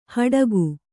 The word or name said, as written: ಹಡಕೆ